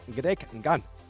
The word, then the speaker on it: Greg Gant: Como decíamos, pendientes de Greg Gant, es así como se pronuncia, el nuevo jugador, decíamos, del Club Baloncesto Ciudad de Ponferrada.